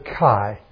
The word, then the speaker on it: kai: The word "even" is the Greek word kai. K-A-I is the way we would spell it, kai.